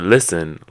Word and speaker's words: listen: Or listen. Listen, you don't say listen. Like you don't pronounce the T at all, but you write it like that.